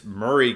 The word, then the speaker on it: Maury: I remember this is something I've remembered is like some people have been pronouncing as Maury County. It's Murray County and Tennessee way, or the Tennessee way of pronouncing it. It's M A, you know, like Maury Povich,